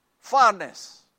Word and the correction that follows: Upharsin: Did I say it right? Upharsin, upharsin.